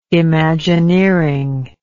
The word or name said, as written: imagineering